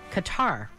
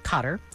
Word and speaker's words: Qatar: And when he was very, very young, Sean left for Qatar, or Cotter, depending on how you say it,